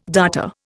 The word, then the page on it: darter: Indian